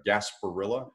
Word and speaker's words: Gasparilla: South Carolina and the Gasparilla. Am I saying that right? The Gasparilla Bowl. – Yes.